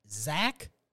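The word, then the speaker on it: Zacch: It's a normal name, but it's spelled really weird. Zach Pickens, but it's Z-A-C-C-H. Two C's and an H. Because God forbid they just be normal and say Z-A-C-H.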